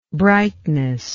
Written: brightness

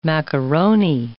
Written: macaroni

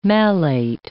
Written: malate

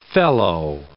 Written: fellow

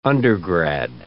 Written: undergrad

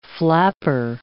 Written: flapper

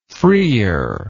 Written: freer

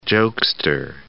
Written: jokester

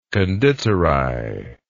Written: Konditorei

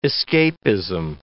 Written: escapism

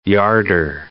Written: yarder

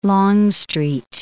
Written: Longstreet

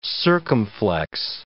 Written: circumflex

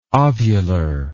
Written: ovular

